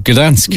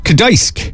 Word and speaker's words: Gdańsk: I would have said Gdansk. – Yeah, me too. – Gdansk. But apparently, it's Gdańsk. So in Poland, that place is pronounced Gdańsk.